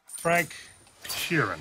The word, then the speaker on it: Frank Sheeran: Frank Sheeran. Am I saying that right? – Yeah, you said right.